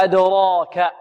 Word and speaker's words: أدراك: وما أدراك، أدراك هذه مرسومة ياء، الألف هنا رسمت ياء، رسمت ماذا؟ ياء، وتنطق ألفا، ويقال له في الرسم هذا يقال له إقلاب، إقلاب. هنا ترسم ماذا؟ ياء، وتنطق ماذا؟ ألفا.